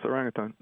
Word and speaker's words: orangutan: Have I pronounced that correctly? – Mm-hmm. Yes, orangutan. Mm-hmm. Man of the forest. – Man of the forest. – Orang is man in Indonesian, and hutan is forest.